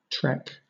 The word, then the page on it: trek: British English